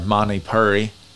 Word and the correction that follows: Manipura: or Manipura, excuse me, and that's probably still pronounced wrong, so shoot me,